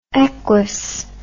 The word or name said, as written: eques